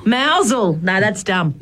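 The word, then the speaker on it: Mousehole: Oh, it's actually mousel. – Mousel. – But it's spelt mouse hole. – It is spelt like mouse hole.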